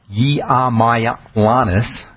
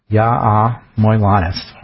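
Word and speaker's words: Yiamouyiannis: by Dr. John Yiamaylanis. I think that's how he says it. Yiamaylanis. Hard to pronounce that one.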